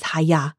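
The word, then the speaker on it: Haya (היה): In Hebrew, the word for I am is Haya, the pronunciation of which originates deep down in the throat. Think of the loud karate expression here.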